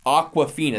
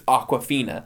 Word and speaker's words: Awkwafina: And first of all, I mispronounced it terribly. And then as I was typing it out, I was looked at it, I'm like, Awkwafina. That's what it's Awkwafina.